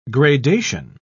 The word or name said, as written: gradation